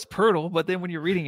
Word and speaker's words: Poeltl: It's Jakob Poeltl, but spelt like Jacob Poeltl.